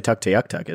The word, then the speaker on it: Tuktoyaktuk: We've got one listener in the Northwest Territories in Tukoyatakutut. Probably butchered that. – One that people say Tuktoyaktuk, isn't it? – That might be it.